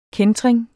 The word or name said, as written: kæntring